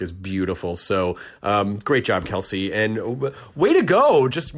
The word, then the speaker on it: Qellessi: Instagram is, it's pronounced Kelsey, but it's spelled It's the at, and then QELLESSI.